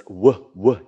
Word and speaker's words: would: Most Japanese pronounce it would, but it's wuh. You have to have the W sound.